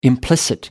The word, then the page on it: implicit: American English